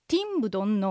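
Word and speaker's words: 听不懂: はい、ティンブドンのティンは聞くという意味です。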